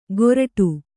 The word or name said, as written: ಕೋರ್ಟು